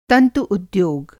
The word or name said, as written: तंतु-उद्योग